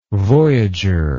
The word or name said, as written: voyager